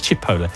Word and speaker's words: Chipotle: Another one, which I must admit, I remember stumbling on in my early days, going to Mexican restaurants, is Chipotle. Which, of course, is spelt sort of Chipotle, and people often say it as Chipotle or yeah. Chipotle or something like that, but it's Chipotle.